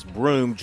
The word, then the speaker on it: Broome: I said Brown. His name is Broome, Johni Broome, 6'10 center forward out of Plant City, Florida.